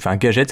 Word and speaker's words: Gaget: Monsieur Gadget, Gadget, ça s'écrit G-A-G-E-T, décide de fabriquer des modèles miniatures de sa grande statue.